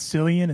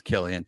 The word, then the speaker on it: Cillian: It's not Cillian. It's Killian. – It's Killian.